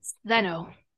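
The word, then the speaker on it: Stheno: Yeah, because they basically tell Stena, am I saying it right? – Steno.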